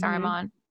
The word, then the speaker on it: Saruman: Saruman? – Yes.